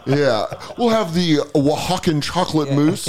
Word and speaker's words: Oaxacan: I want the Oxycontin chocolate mousse cake. And I went, I don't think that's how you say it. And I think it's Oaxacan chocolate mousse.